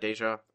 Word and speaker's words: Dejah: Dejah, I'm assuming.